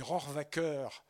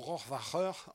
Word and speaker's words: Rohrwacher: Alors, je demandais tout à l'heure à Carlo parce que c'est Rohrwacker en italien ou Rohrwacher en allemand, puisque ses parents sont allemands et qu'elle est italienne. Donc vous prononcerez comme vous le souhaitez, à l'italienne ou à l'allemande.